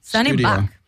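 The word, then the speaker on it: Sunny Bak: Sunny Bach.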